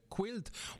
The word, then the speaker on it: Quilt: Ah, ça va super bien, on va parler de Quilt. On prononce ça comme ça, Quilt? – Oui, absolument, ah, c'est la bonne prononciation, oui.